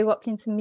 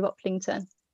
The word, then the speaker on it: Waplington: Yeah, so Instagram at Chloe Waplington Music and Facebook Chloe Waplington. – There you go, easy as. And Waplington is spelt W-A-P-L-I-N-G-T-O-N.